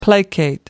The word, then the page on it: placate: American English